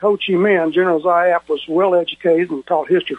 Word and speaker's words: Giap: It's spelled G-I-A-P. But it's pronounced Zap.